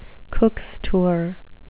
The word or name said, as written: cooks tour